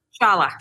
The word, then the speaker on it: Shala: We'll talk more about, is it Shayla 606? I forgot to ask about pronunciation. Shayla 606, right? – Shala 606.